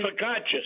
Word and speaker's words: focaccia: Focaccia, excuse me, I said it wrong.